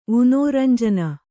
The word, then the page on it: ವುನೋರಂಜನ: Kannada